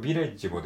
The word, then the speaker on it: village: はい、カレッジ・ビレッジってこれね、なんかこう、あの、ラップの韻を踏んでるところみたいな感じですけども、これビレッジもですね、あの、発音記号的にはカレッジの、えー、後半と同じ発音ですね。